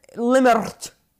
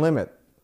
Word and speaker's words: limit: What if I said limert? – Limert. Kelly, well, there's no R in limit.